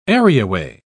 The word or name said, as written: areaway